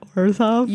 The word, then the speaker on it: Orzhov: I'm going to give you a clue. It rhymes with Borzhov. – Is it Orzhov? – You nailed it.